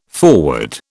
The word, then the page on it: forward: British English